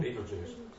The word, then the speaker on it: Betelgeuse: There's a star called Regal, I think that's how you pronounce it, and then Beltegeuse or something. – Betelgeuse.